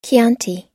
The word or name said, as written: chianti